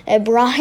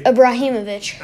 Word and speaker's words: Ibrahimovic: Abramovich. I don't even know how to say his name. – Ibrahimovic. – Either way. – Ibrahimovic. – I think that's closer than what I was.